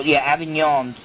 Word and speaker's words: Avignon: I believe it's in France, and because of that, you probably don't even pronounce the G, whatever. Avignon.